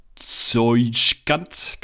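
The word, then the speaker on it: Tsojcanth: Zeuschkant.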